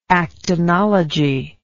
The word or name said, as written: actinology